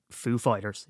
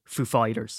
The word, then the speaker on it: Foo Fighters: And of course he said, instead of saying Foo Fighters, he said Foo Fighters. So you put the emphasis on the second one and he goes, ladies and gentlemen, Foo Fighters.